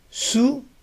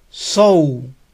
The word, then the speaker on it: sow: Su, no. Este verbo se pronuncia igual que sembrar. Sow, sow. Y significa coser, con S, cuando hablamos de coser con un hilo. ¿Sabes cómo coser esos jeans? Do you know how to sew those jeans? Recuerden, sow, sembrar, tiene la misma pronunciación de sow, coser.